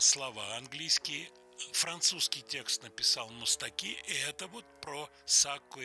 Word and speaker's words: Мустаки: Так вот, мы послушаем песню в исполнении Жоржа Мустаки, Мустаки, греческая фамилия, французский, по-французски она звучит, с ударением на последнем. Слоги «Марш» Сакко и Ванцетти.